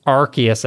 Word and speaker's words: Arceus: Arceus, I believe.